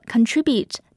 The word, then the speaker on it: contribute: 注意 contribute 做动词时，重音可以在第一个，也可以在第二个音节。在第一个音节时，读作 contribute。接着说一说 contribute 的名词形式 contribution。